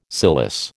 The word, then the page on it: cilice: American English